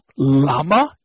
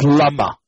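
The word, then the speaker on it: Lama (למה): However, if there's Aleph, Ayin, or a Hey, so it's not Lama, it's Lama. Lemosho, Lama charalecha v'lama naflupanecha. Lama, right? The word is atachet, is mil'el, is the, what you call, the emphasis is on the first syllable.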